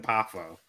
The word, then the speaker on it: Poffo: Or Lanny Popoff, sorry. Lanny Popoff. Poffo? I don't know how to fucking pronounce it. Poffo. Lanny Poffo. P-O-F-F-O.